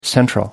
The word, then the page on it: central: American English